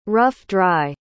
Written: rough-dry

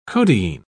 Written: codeine

